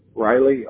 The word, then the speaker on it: Riley: It's J-O-S-E-P-H. – That's right. Okay. Thank you. And what is your last name? – Riley, R-I-L-E-Y.